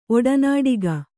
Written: ಒಡನಾಡಿಗ

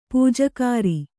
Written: ಪೂಜಾಗಾರ

